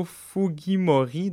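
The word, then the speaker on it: Fujimori: Il y a un Péruvien descendant japonais plus connu que d'autres, Alberto Fugimori. Désolé si je, je, prononce euh, mal. Et... J'aurais eu tendance à le dire à peu près pareil.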